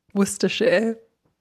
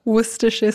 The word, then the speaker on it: Worcestershire: Worcestershire. – E in una volta. – Worcestershire Sauce.